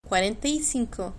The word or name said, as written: cuarenta y cinco